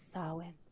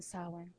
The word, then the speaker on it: Samhain: My favorite era is, to say it like Danzig would, is Samhain. It's his era in Samhain. Samhain. – All right. – That's how he says it.